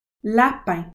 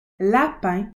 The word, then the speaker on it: lapin: souris, mouse, lapin, rabbit, chèvre, goat, mouton, sheep, vache, cow, chien, chat, souris, lapin, chèvre, mouton, vache.